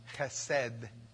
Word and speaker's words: chesed: It's the word chesed.